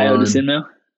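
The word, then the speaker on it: Ayo Dosunmu: Ayodesunmu.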